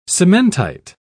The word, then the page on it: cementite: British English